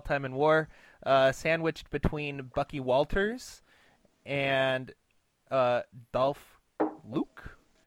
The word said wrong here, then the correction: Luque: Luque. Dolph Luque. Thank you. Thank you, Baseball Reference pronunciation guide.